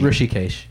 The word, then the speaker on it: Hrishikesh: Under pronunciation of your name on your Twitter, it says it's pronounced R-U-S-H-E. So that Rishi. To me is Rushi, not Rishi. – Rishi. Hrishikesh. – Yeah. Rishi should be R-I, not R-U.